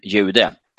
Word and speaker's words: Jude: The J in the German Jude is pronounced exactly like the English Y in just youth and jocht.